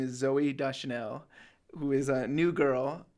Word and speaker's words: Deschanel: And it was directed by Caleb Deschanel. And I guess I'll say it right here, Deschanel.